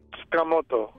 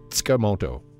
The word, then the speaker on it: Tsukamoto: Tsukamoto. – Tsukamoto. – Tsukamoto, yes. – With a T, Tsukamoto. – Yes, that's right.